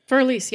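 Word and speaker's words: Für Elise: We just see him at a concert playing Fur-Elise. I'm not saying that right. – Fur-Elise, yeah. – Yeah, am I saying that right? Okay, great.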